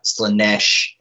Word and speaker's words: Slaanesh: There's Khorne, Sneech, I never say that right, Slaanesh, and Nurgle.